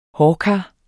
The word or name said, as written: hårkar